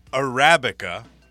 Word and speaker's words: Arabica: And that's how you pronounce it. Because I went to YouTube, I looked at three different pronunciations. It is Arabica beans. I got it right.